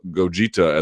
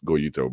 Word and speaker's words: Goyito: This is a bit of a come-to-Jesus moment for Gogeta as well. I know it's Goyito, but Gogeta is how my wife would pronounce it.